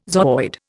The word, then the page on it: zooid: Indian